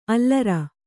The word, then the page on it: ಅಲರ್: kannada